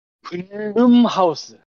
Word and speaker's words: 블룸하우스: 블룸하우스. – 예. – 블룸하우스. 블루 예. 마우스가 뭐야? – 블루 마우스라고 얘기했어요. – 아, 그 발음이 뭉개져서 블루 마우스로 들렸어 나도.